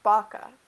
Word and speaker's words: Baca: Because Corey said Baca like the cow, and my mom says there's a kind of cow that's spelled Vaca but pronounced Baca.